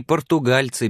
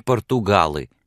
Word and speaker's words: португальцы: «Классы окончились, и почти все они португальцы, Билли, а не португалы. Мерседес научила меня правильно произносить это слово».